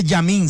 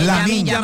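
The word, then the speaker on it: Lamine: Dijo Yamin. Si ve que uno se equivoca al pronunciar su nombre. – Es Lamin.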